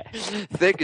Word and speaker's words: THQ: Thick is definitely the best way to pronounce. It's the way pros pronounce THQ.